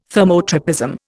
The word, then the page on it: thermotropism: Indian